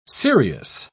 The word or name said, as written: serious